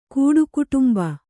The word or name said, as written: ಕೂಡುಕುಟುಂಬ